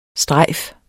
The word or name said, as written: strejf